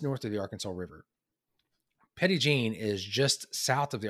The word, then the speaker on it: Petit Jean: So, I mean, the most popular one is what locals call Petit Jean. It looks like Petit Jean. If you look it up on the map, they pronounce it Petit Jean.